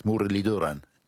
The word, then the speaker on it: Muralitharan: Yes, the th is a d, which is a dental t with, I think, your tongue touching your teeth, Mudaliduran. Did I sound roughly right? That's right. Oh, beautiful. You've pronounced it perfectly.